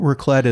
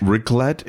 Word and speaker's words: raclette: And also, Probably the signature dish of Switzerland is called raclette. Have you heard of raclette? – This sounds familiar to me. Tell me again what raclette is, and I'll tell you if I'm thinking of that or if I'm thinking of I'm making a mistake.